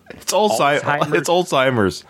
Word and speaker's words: Alzheimer's: Are you not pronouncing the Z? – I might not be. Alzheimer's? – It's Alzheimer's.